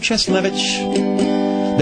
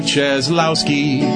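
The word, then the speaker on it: Cieslewicz: Madison Mayor Dave Cheslevich, that's the right way to pronounce ski. Please don't say it like Obama did. He called him Mayor Dave Cheslowski.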